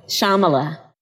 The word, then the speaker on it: Shyamala: Shyamala. – Shyamala.